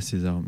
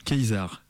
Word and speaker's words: Caesar: Je sais pas pourquoi je dis César depuis tout à l'heure, en fait, parce que c'est pas César, mais... Caesar. – Caesar, mais je pense que ça se prononce César. – Ouais. – Comme la salade.